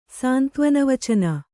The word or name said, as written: ಸಾಂತ್ವನವಚನ